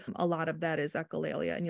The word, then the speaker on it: Echolalia: Echolalia, is that how you? – Yes.